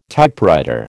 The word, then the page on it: Typewriter: American English